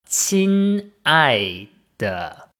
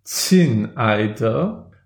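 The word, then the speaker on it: Qin Ai De (亲爱的): Dann sage ich Qin Ai De. – Oh ja, das stimmt. Das klingt ganz, würde ich auch nicht anders sagen. Ja, aber du wolltest sagen, klingt ganz gut ausgesprochen.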